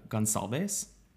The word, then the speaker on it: Goncalves: And that would lead us to the fourth victim, which is 21 year old Kaylee uh, Goncalves, but I've heard, I've heard other people pronounce it uh, Gonsalves.